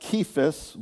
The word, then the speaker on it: Cephas: I noticed in the film they pronounce it Cephas with a K sound.